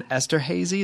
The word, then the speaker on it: Esterhazy: And you grew up in Esterhazy, is that how you pronounce that? – Yeah, potash capital of the world.